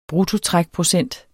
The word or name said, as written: bruttotrækprocent